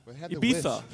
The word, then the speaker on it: Ibiza: You don't say Ibiza. – Ibiza. – He's in Ibiza. – That's how you say it's Spanish.